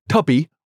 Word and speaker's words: Tubi: So, how do you spell it? – T-U-B-I. Tubi. – Like Mubi, but Tubi. – Tubi.